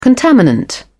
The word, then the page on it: contaminant: British English